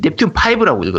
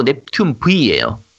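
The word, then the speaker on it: Neptunia V: 그러니까 제아도무늬 같은 사람들이 읽으면 넵튠 5라고 읽을 거야. 그렇죠. – 네. – 근데 넵튠 5가 아니고 넵튠 V예요.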